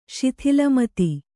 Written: ಶಿಥಿಲಮತಿ

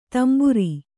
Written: ತಂಬುರಿ

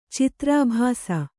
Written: ಚಿತ್ರಾಭಾಸ

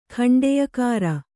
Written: ಖಂಡೆಯಕಾರ

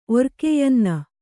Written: ಒರ್ಕೆಯ್ಯನ್ನ

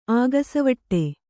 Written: ಅಗಸೆವಟ್ಟೆ

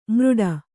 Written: ಮೃಡ

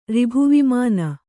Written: ರಿಭುವಿಮಾನ

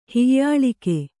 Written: ಹೀಯಾಳಿಕೆ

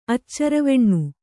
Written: ಅಚ್ಚರವೆಣ್ಣು

